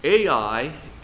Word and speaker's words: Ai: And then We went to the sister cities of Ai, and actually the correct pronunciation is I. You know, Ai, literally Ai, and I learned that the correct pronunciation is just I, but Dr. Bookman said, you know, you can still call it Ai.